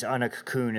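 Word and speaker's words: Anach Cuain: So I know I referenced it as Anac Cuan and Anac Duin and Anac Down a couple times, and depending on what time frame you're referencing this location as, it changes the pronunciation. So the original pronunciation, if you read it phonetically, is Anac Down, but this eventually changed. Anac Cuan is the contemporary pronunciation.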